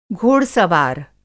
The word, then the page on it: घोड़-सवार: Hindi